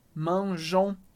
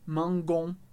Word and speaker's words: mangeons: Porque esse e, ele só ajuda a pronúncia, para manter o som j. Manjon. Porque se a gente tirar o E, ficaria mangon. Isso não existe, é estranhíssimo.